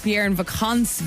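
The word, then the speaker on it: Pierre et Vacances: So your lovely mother, Margaret, Fiona, yeah, she's the one who told us that we've been pronouncing it wrong for a long time, and apparently it's Pierre and Vacance Village.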